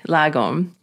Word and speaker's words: Lagom: And I have to ask you about your latest book, Lagom. I hope I've pronounced that right. – Yeah.